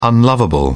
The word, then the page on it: unlovable: British English